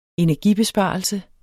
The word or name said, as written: Energibesparelse